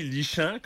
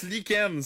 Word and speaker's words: lichen: Quelque chose qui se lit lichant, comme disait Marion. – Ben ouais, tu... – Pourquoi on le prononce lichen? C'est quoi le rapport? – Ça devrait être écrit comme L-I-K-E-N-N-E.